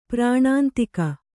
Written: ಪರನಿಂದಕ

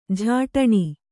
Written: ಝಟ್ಟನೆ

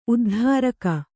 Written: ಉದ್ಧಾರಕ

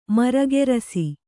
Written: ಮರಗೆರಸಿ

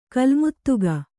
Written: ಕಲ್ಮುತ್ತುಗ

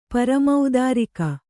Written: ಪರಮೌದಾರಿಕ

